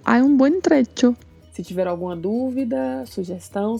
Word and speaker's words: trecho: Lembrando que se fala com o som de uma palavra que estamos habituados a falar, que é tchau. Então pronunciamos dicho, hecho, trecho.